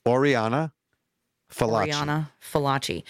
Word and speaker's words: Oriana Fallaci: Oriana Fallaci.